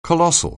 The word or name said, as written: colossal